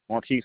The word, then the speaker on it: Marquis: Marquise Harris. – Could you spell that for me? – Marquise Harris, M-A-R-Q-U-I-S.